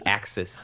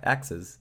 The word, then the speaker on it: axes: Okay, I now have eight axes. How do you say that? What's the plural, of axis? Axi? – Yeah, axes. Axes. – Axes. Thank you.